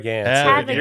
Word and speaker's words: Gansett: Want a Gansett. Did I say that right? – Have a Gansett. You were close.